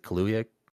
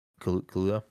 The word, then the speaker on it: Kaluuya: Daniel Kaluuya. I don't know how to pronounce that. Is it Kaluuya? Kaluuya.